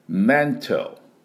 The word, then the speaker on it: mental: まずメンタルの正しい発音ですね、 mental になります。で、あの M はうーんどうしようかなのうーんと音を出してね、唇を閉じて唇を振動させます。